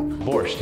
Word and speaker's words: Borscht: Borscht, did I say it right? – Yeah.